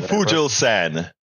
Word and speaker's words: Fugelsang: It's John Fugelsang.